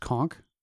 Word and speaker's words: Conques: But that abbey in, I thought it was Chereau, but it's pronounced Conques.